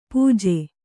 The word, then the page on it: ಪಾಜೆ: pāje; kannada